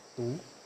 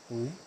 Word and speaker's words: Tu: My name is Tu. – Tu. – Tu. It's going up, the tone's going up.